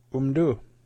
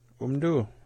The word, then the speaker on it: Umdu: Umdu. Umdu. That's how you say it. – Umdu.